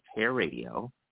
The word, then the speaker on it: HAIRadio: So it's pronounced Hair Radio with just one R in the middle.